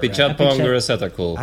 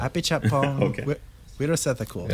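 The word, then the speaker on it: Apichatpong Weerasethakul: Yeah, it sounded like you said Apichatpong Wirasethakul. – Apichatpong Wirasethakul.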